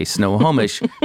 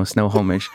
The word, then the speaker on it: Snohomish: And it's funny, when I used to call up and have to give my address over the phone and I would say Snohomish, people would often giggle. It sounds somehow like the North Pole. I don't know, Snohomish. But it's spelled exactly like it sounds, Snohomish, which you can tell I've said a million times in my life.